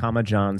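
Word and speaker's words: Jon: Yeah, I'm at Arnold, John, spell it all out, and no H in John.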